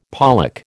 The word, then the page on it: pollack: American English